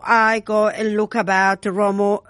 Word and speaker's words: Remo: Is That's it, in Italian. Remo and Romolo. – Is the correct pronunciation.